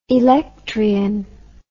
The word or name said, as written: electryon